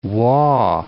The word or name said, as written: Waugh